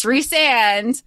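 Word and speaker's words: Rhysand: Hot take, it's Resand. Don't know what I don't care what anybody else says, because that's what it, like, that's how it's supposed to be pronounced.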